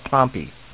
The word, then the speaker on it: Pompey: In a town that's spelled like Pompeii, but the locals call it Pompey, which I guess is about, 2,200 feet up or so, somewhere around there.